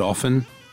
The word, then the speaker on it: often: By the way, the T is silent in the word often.